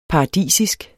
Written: paradisisk

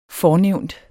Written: fornævnt

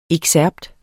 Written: EXCERPTERNE

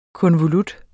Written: konvolut

